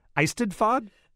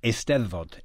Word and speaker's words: Eisteddfod: Now, you, as we're talking, you just recently got back from an event in Wales, a national Eisteddfod? Am I saying that right? – You're close. Eisteddfod. – Eisteddfod. I wasn't that close.